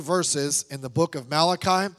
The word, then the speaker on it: Malachi: If you're Italian, you could pronounce it Malachi, whatever. But it's Malachi, okay?